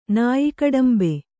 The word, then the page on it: ನಾಯಿಕಡಂಬೆ: kannada